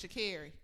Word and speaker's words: Sha'Carri: is it a hard C or a soft C? I just fucking because I'm pronouncing Sha'Carri. – That's how I thought it was too. – The hard, so it's Sha'Carri.